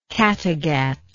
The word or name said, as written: Cattegat